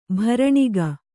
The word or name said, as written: ಭರಣಿಗೆ